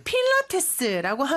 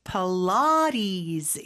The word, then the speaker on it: Pilates: 필라테스인데 발음이 참 독특하죠. 그렇죠? 필라테스라고 하면은 사실 원어민들은 못 알아들을 수 있을 것 같아요. – Yeah. – Pilates. 이렇게 발음을 합니다.